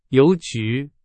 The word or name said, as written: 邮局